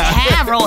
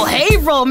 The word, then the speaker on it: Haverhill: Not Haverhill. Haverhill, man.